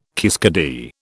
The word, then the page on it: kiskadee: British English